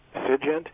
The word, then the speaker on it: SIGINT: For the document I'm referring to is, I think it's pronounced SIGINT, S-I-G-I-N-T.